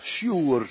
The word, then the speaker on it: Sur: Kijk maar, Israël gaat van de Schelfzee naar de woestijn, Sjoer, Sur staat er, Sjoer spreek je het uit.